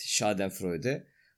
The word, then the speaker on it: Schadenfreude: Schadenfreude. Yine kesin doğru telaffuz etmiyorum ama. – Schadenfreude. – Evet, Schadenfreude.